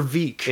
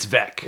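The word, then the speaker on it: Veeck: I'm just going to like Bill Bill Bill so is it Vec or Veek? It's Vec. It's Vec. Okay, so I've yeah. always been pronouncing this wrong.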